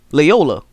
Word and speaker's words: Loyola: I was calling them Lower Chicago or Larry Chicago last week on the show. I'm still going to butcher this. Loyola. It's Loyola. It's Loyola, Illinois.